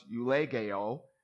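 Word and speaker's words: eulegeo (εὐλογέω): The word "bless," it comes from a Greek word pronounced eulegeo, and it's the Greek word, as you can see it up there, eulogeo, from which we get our English word eulogy, which means a good word.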